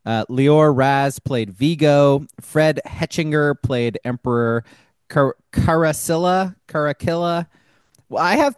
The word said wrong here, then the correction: Caracalla: I think it's Caracalla.